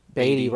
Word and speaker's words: Beatty: Is that how you say his name? Beatty? Beatty? – Beatty. – Beatty, right? Okay, Ned Beatty.